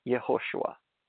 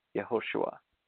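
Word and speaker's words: Yehoshua (יהושע): How do you pronounce that in Hebrew? Yehoshua. That's right, Yehoshua.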